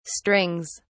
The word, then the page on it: strings: British English